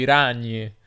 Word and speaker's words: ragni: Comunque adesso so come si pronuncia correttamente. Ragni.